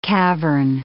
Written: cavern

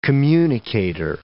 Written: communicator

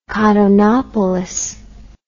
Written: Cottonopolis

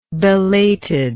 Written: belatedness

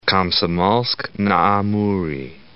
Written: Komsomol'sk-na-Amure

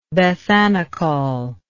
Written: bethanechol

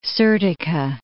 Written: Serdica

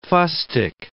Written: fustic

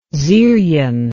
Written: zyryan

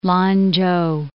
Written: Lan-chou

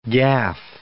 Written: gaffe

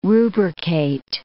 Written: rubricate

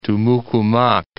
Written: Tumuc-Humac Mountains

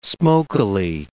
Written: smokily

